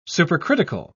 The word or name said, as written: supercritical